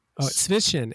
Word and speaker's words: Zwischen: Zwischen.